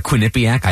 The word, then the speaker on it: Quinnipiac: Also Quinnipiac, which may I just point out, I am very proud to know how to pronounce uh, Quinnipiac.